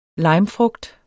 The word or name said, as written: limefrugt